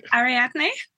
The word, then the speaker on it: Ariadne: Ariadne.